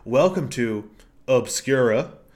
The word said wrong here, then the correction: Obscurum: Um, uh, Obscurum. My apologies. I said it wrong.